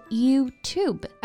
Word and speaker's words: YouTube: Yes, and YouTube is a compound word made up of you and tube, so it's important to pronounce each syllable separately. 所以它的正确发音应该是 YouTube.